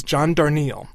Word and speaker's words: John Darnielle: The singer, whose name I have been mispronouncing for years and years and years, is John Darnielle.